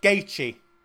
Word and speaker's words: Gaethje: How do you pronounce his surname? – Uh, I think Gaethje. it's Gaethje. Gaethje. Gaethje.